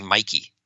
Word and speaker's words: Mickey: But in Chinese, somehow when you translate Mickey's name, it's not pronounced Mickey. It's pronounced almost like Mikey.